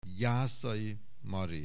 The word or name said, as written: Jászai Mari